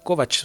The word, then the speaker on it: Kovacs: Kovacs, según él se pronuncia. Según él mismo dice en algún momento, se pronuncia Kovacs.